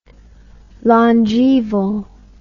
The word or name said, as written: longaeval